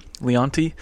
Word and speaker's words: Leonetti: Did you say his name? Leonti? – There you go.